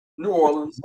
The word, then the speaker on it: New Orleans: So tell me, how do you say New Orleans? – New Orleans. – Right way to say it. – New Orleans, just like that.